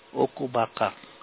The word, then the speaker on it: Okoubaka: How do you spell it again, okubaka? – O-K-O-U-B-A-K-A. – Okay. – Okubaka.